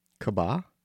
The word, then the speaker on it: Kaaba: Kaaba? – How do you spell it? – K-A-A-B-A.